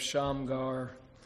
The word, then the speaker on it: Shamgar: One verse here, he's mentioned again later in Judges, but that's the account of Shamgar, accent on the last syllable.